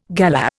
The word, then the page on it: galah: Indian